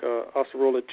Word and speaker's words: acerola: And some of the properties of these fruits, like acerola cherries, I believe I said that right, Jihad? – Yes, you did.